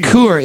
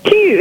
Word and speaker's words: cul: All right, so it's not coo, it's what is it, honey? It's key. You're saying more like heart. – Okay, and how should I pronounce it? – Q. It's more Q.